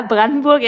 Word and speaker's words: Brandenburg: I'm going to butcher the second part, Brandenburg or something. – Yeah, Brandenburg.